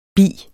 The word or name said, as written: bis